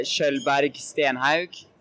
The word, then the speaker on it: Skjoldberg Stenhaug: I say Skjoldberg Stenhaug. – Okay. – But in England, I just use Stenhaug. – Okay. – I just say it like that.